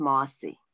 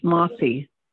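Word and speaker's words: mahsie: And that's also, I assume, a French loanword for merci, but jargon is pronounced mossy. Mossy. – Mm-hmm. – Mossy. – Yes. Like mossy, moss on a branch or the rock or something. That's the way it's pronounced, mossy.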